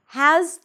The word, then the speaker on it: has: Now, has to follows the same rules. Has, there is a z sound at the end, even though it's spelled with an S, it's a Z.